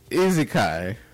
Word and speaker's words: isekai: Yeah, isekai. Isekai. How you know? I'm saying it wrong. – Like, yeah, Monster, because I'm. So I'm in this, man. Like, I'm in this. – Man, get the hell out of here. It's easy kai.